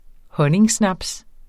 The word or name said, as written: honningsnaps